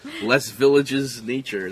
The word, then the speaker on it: Les Villages Nature: Do you pronounce the S? Les Villages Nature. That's my Brooklyn version. – That sounds good to me.